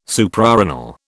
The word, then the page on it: Suprarenal: British English